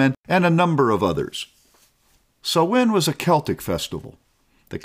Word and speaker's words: Samhain: Spelled S-A-M-H-I-N, it is pronounced S-O-W-W-I-N, Samhain.